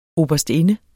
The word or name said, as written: oberstinde